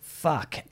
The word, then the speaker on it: PHUK: And, yeah, PHUK, but definitely pronounced Fuck.